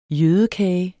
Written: jødekage

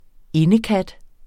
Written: indekat

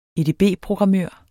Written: edb-programmør

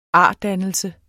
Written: ardannelse

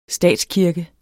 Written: statskirke

